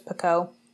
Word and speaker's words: Picoult: So I'm in the middle of a Jodi Picoult book. It's called A Spark of Light. I love reading Jodi Picoult's books, and I hope I'm saying her last name right. I'm pretty sure it's Picoult. Picoult? P-I-C-O-U-L-T is how it's spelled.